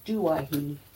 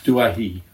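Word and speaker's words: Douaihy: I believe it's pronounced Duahi. – Duahi, okay.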